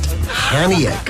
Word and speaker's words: Chania: It's pronounced Chania, guys.